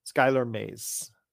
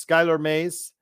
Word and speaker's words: Skylar Mays: Skylar Mays. Yes, that's correct. See, we played so well and played so much that kind of mispronounced his name. So yes, Skylar Mays.